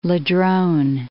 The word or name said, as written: Ladrone Islands